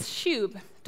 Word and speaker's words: shub: shub. Don't worry, I looked up the pronunciation, that is how you say it.